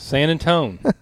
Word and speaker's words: San Antone: San Antone. That's how they say it.